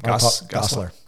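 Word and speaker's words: Gosselaar: I don't know how to say his last name. – Gosler. – Okay. Yeah. So it's easier to say than it looks.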